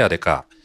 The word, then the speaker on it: ADEKA: 会社名、株式会社アルファベットで ADEKA と書いてアデカ、証券コード 4401、本社は東京都荒川区にあります。